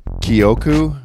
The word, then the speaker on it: Kyoku: Kyoku, I'm really starting to get the pronunciation down. It's K-Y-O-K-U. Kyoku. Say it with me, everybody. Kyoku.